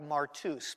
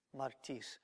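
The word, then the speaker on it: martis (μάρτυς): If you looked at the Greek, it would look like martus, but that upsilon is pronounced like an e, martis.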